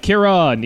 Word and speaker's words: Kieran: Kieran, yes. Kier and then on, like you're switching something on.